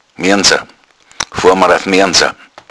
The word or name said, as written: moernsa